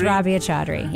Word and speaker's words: Rabia Chaudhry: Is it Rabia? – Yes. – Am I saying it right? Rabia Chaudhry? – It's Rabia Chaudhry, yes.